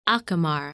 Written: Acamar

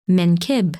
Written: Menkib

